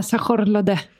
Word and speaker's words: sayur lodeh: sayur lodeh. Alltså jag har ingen aning om hur det här uttalas, men det är typ... Jaha, du menar sajorlode.